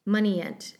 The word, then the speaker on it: Munigant: The other important person in this, let's see if I can Moneyant. Yeah, that's the name of the doctor, is Dr. Moneyant, although it's spelled Munigant.